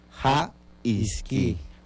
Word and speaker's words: ja-isqui: En lengua yocotán pues se pronuncia ja-isqui.